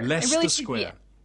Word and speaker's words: Leicester Square: So you just have to get rid of some letters, and you can pronounce Leicester Square. – Leicester Square.